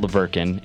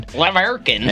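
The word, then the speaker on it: La Verkin: Leverkin. – And they couldn't fucking pronounce La Virgin, so they're like, we're going to call it Leverkin. So that's why they fucking called it Leverkin.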